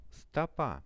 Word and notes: stopa: Russian

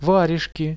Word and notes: varezhki: Russian

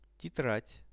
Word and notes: tetrad: Russian

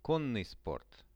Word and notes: konnyi sport: Russian

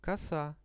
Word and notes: kosa: Russian

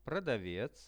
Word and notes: prodavec: Russian

